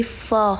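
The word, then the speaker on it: phở: فو نوشته میشه ولی فا خونده میشه فا هم نه یعنی آ هم نه یه چیزی بین ا و آ فا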